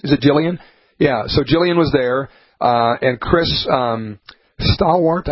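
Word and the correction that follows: Stirewalt: I always say his name wrong, but Steyerwalt, the guy that does all the political analysis, pretty good.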